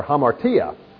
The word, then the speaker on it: hamartia: Nomos law, negates it, like an atheist, anomias, lawlessness, or hamartia, which despite the H sound at the beginning, begins with an alpha, also in Greek,